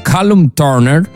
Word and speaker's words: Callum Turner: Lei, ricordiamo, si è sposata con quello che era il suo fidanzato, era suo marito Callum Turner. Questa è la pronuncia inglese, avete visto quanto sono madrelingua.